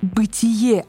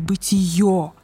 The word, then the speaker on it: бытие: Да, и кстати, если вы еще не знаете, правильно говорить «бытие», а не «бытие».